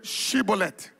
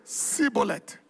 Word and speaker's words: Shibboleth: If he said no, they said, "Okay," unto him, "Now say Shibboleth." And he said, "Sibboleth." Because he couldn't pronounce the H, the she.